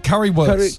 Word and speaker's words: Currywurst: Currywurst.